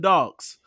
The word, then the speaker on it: Dog: which is food and dogs, creating what is believed to be the first restaurant exclusively for man's best friend. Dog, which rhymes with Vogue, opened last month.